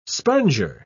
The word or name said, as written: sponger